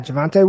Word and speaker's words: Javonte: Yeah, Javonte Williams. – Yes, okay, it looked like Devonte, but with a J.